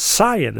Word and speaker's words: scion: They said, "What is it? Scion? Scion? The word is scion."